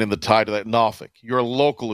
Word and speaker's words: Norfolk: Can you pronounce the way you say Norfolk again, please? – Norfolk. – Norfolk. – That's how they say it up there. – It's Norfolk.